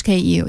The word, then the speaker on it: KU: How do you put KU? KU, the pronunciation for your company, Kyo? – In English, KU, yeah.